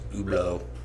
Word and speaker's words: Hublot: That's spelled Hublot, by the way, and it's pronounced Hublot in French.